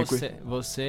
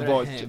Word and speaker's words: Você: E come si pronunciava bene? – Você é o... – Você è, vedi, no voce.